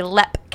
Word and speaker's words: Leap: So the castle that I'm doing is, I thought it was Leap Castle because it's spelled L-E-A-P, but it's actually Lep Castle. – Because it's Irish.